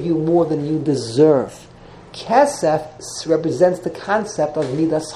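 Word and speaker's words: kesef (כסף): Silver is kesef, to a double segel.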